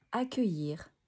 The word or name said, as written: Accueillir